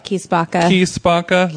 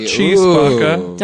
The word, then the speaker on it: Chi Spacca: Osteria Mozza, and Key Spacca. – Not Cheese Spacca.